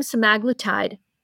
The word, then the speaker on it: semaglutide: They gave them semaglutide. So actually, guys, did I pronounce that correctly? You're closer to GLP-1s than I am. – Okay. That's how I say it.